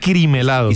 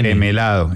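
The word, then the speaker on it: Crem Helado: Como el creme helado, porque ese creme es sin la A. – Es cream. Además, yo vi muchas versiones que era cream helado. – Y se escribe creme. – Exacto, creme, no cream, exacto.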